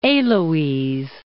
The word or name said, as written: Heloise